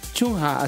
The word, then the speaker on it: Chungha: Es C-H-U. – C-H-U. – N-G-H-A. – Chungha, ¿así? – Sí. – A ver. Veamos cómo lo dice Google. – Chungha. – Chungha. – Chonga.